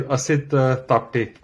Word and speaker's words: Asit Tapte: Yeah, it is Asit Tapte.